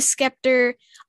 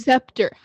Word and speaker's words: scepter: I love the scepter. I love the cloak. I love the scepter. How could you say that wrong? It's scepter.